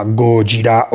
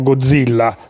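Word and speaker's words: Gojira (ゴジラ): Quindi alla fine Godzilla si chiama Gojira o Gojira. Dopodiché noi lo chiamiamo Godzilla, dove con questa pronuncia ci mettiamo in mezzo Dio, che non c'entra niente nella versione giapponese, deve essere chiaro.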